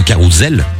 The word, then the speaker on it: carrousel: C'est un carrousel. – Ah, ah. – Oh. D'après la langue française, les amis, un S quand il est entre deux voyelles, il se prononce Z et non S.